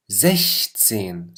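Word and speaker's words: sechzehn: اما لما بتجي بال 16 فبتصير زشتسين، صارت مثل حرف الشين باللغه العربيه، زشتسين زشتسين، بينما هون زكس، لاحظوا مثل حرف الاكس تلفظ هون، هون اكس هون شين.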